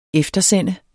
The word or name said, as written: eftersende